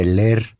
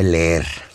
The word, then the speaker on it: leer: No se dice leer, se dice leer.